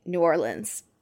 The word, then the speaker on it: New Orleans: You said it right. – Yeah, you did. Yeah, you did. I have to think about it so hard now each time. It's really stressful. – Just think L-I-N-S, New Orleans.